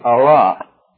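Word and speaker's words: Allah (ἀλλά): It is spelled A-L-L-A, Allah.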